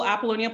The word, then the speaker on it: Apollonia: Yeah, I think the easiest way is just Google Apollonia Ponte and everything will fall, everything will come up. No matter how you spell it, it's still going to happen. So okay. Don't worry. But it's spelled APOLLONIA, the first name.